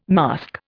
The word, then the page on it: masque: Indian